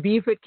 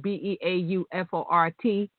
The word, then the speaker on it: Beaufort: They are spelled the same way, but they're pronounced differently. So in Beaufort, it's beautiful Beaufort by the sea. I take it in North Carolina, they're looking at the men because it's Beaufort.